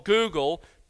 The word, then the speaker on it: googol: The founders of this corporation called Google took their name from a mathematical term, the word Google, pronounced the same but spelled differently. It's spelled G-O-O-G-O-L.